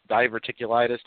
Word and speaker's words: diverticulitis: If I'm not mistaken, that's the one where Lesnar had diverticulitis.